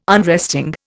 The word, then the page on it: unresting: Indian